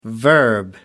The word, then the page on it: verb: American English